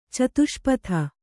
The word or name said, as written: ಚತುಷ್ಪಥ